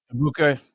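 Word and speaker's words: Egbuka: Abuka, yeah.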